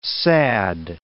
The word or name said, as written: sad